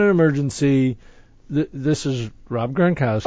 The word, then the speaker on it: Gronkowski: Gronkowski. – Gronkowski.